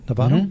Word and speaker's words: Novato: There is Jeffrey, who called in from Novato. Did I say that right? Novato? – Mm-hmm.